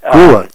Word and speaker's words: Grua: I know that, you know, Harvey had looked into it, and then Kenton Grau had Grua, G-R-U-A, yeah. – Grua, okay. I've never, you know, been aware of how that should be pronounced.